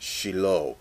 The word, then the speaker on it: Shiloh: As our translations reveal, one camp transliterates the Hebrew S-Y-L-H. Pronounced Shiloh. In the Hebrew, it's just the consonants, and you have to supply the vowels. And most commonly, Shiloh.